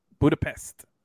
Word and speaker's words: Budapest: Pest. You do the Pest? – That's how you're supposed to pronounce it. – Budapest. I've seen that. Yeah, I know. – Yeah, I know how it's spelled. I'm just saying how it's actually said.